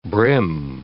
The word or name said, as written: bream